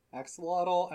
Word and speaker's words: axolotl: It was like in a giant axolotl? Axolotl? I don't know how to say it. Axolotl, yes.